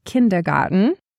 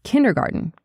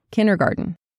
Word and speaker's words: kindergarten: And notice here, I'm pronouncing these like an American because we have an expected pronunciation for them. It's not kindergarten, it's kindergarten, kindergarten. So you can't almost hear that D or the T in the word.